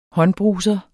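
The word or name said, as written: håndbruse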